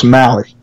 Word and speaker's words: Mahle: Let me see if I can say this right, man. Mahle. – Close, Mahle.